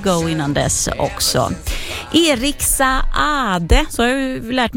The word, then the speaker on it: Saade: Han stavar ju S-A-A-D-E, och då ska man tydligen uttala båda A-N också, så då gör jag det.